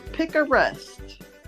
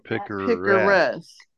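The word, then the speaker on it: picaresque: Our first word is pick a rest. And you spell it P I C A R E S T. Oh, S Q U E. – Pick a rest. Yeah, it's not picturesque because that means like it's, you know, very like a picture, like a, you know, a picture that's nice to look at.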